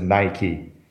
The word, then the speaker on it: Nike: Heißt es nun Nike oder Nike? Wie sollen wir es in Deutschland aussprechen? – Ja, die Leute haben das immer noch nicht so richtig. Vielleicht liegt es ein bisschen an Nike, aber die richtige Aussprache für die Firma ist Nike.